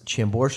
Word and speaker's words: Chambord: It's aged in, I'm going to mispronounce this, Chambord wine barrels. – That sounds right.